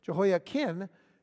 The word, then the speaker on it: Jehoiachin: But after rebelling against Nebuchadnezzar, Jehoiakim's son Jehoiachin, C H I N at the end of his name, pronounced almost the same, but with an N like Nancy at the end, Jehoiachin, he became king.